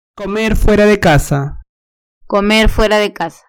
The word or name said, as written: comer fuera de casa